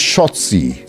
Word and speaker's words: Shotzi: With all the women watching backstage to this match, it is Raquel Gonzalez versus Shotzi. Not just rhymes with Nazi.